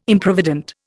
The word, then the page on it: Improvident: Indian